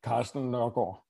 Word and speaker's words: Carsten Nørgaard: Now, before we were on air, we learned that you had a better pronunciation of your last name. Can you give us your actual pronunciation of the last name? – Carsten Nørgaard. – No, there you go. So much stronger, I feel like, than the watered-down American version.